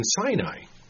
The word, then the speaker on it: Sin: And sure enough, it is pronounced the same way as the mountain. Except we've obviously pronounced the mountain wrong. So according to the three or four sources that I checked, and every one of them were in agreement, the way to pronounce this wilderness was Sin, the wilderness of Sin.